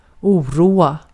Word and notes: distress: /dɪ.ˈstɹɛs/

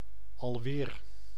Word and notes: alweer: /ɑlˈweːr/; [ɑlˈwɪːr]

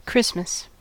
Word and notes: Christmas: [ˈkɹɪs.məs]

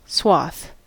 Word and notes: swath: /swɒθ/; /swɑθ/; /swɔːθ/

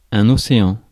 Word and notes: un océan: [œ̃ n‿o.s.eɑ̃]; France

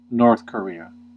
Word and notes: North Korea: [ˌnɔɹθ kə.ˈɹi.ə]; American English

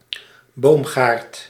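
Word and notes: сад: IPA: [ˈsat]; sad; Russian

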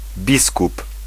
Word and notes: biskup: /ˈbiskup/

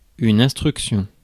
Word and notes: statement: /ˈsteɪt.mənt/

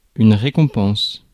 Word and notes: compensation: American English; /ˌkɒmpɛnˈseɪʃən/

